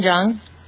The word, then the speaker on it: Jeong: Zhang? How do you pronounce that guy's name? – Ken Jeong.